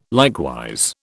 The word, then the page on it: likewise: British English